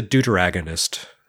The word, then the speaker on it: deuteragonist: but I guess he's a deuteragonist. Is that how you pronounce that? Yes. You have the protagonist, but if there's two of them, it's the deuteragonist.